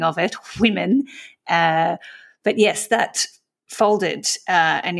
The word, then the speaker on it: Whimn: It's spelled W-H-I-M-N anyway. – Yes, I would assume it's pronounced women because it's Women's Content Network and it's spelt W-H-I-M-N, which is the With Her In Mind Network.